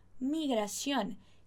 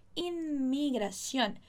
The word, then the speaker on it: inmigración: Muita atenção aqui, ó, vou te pedir para olhar aqui para mim um pouquinho na pronúncia dessa palavra, inmigración, inmigración. Sua língua vai ali no céu da boca para depois vir o migración. Então, inmigración.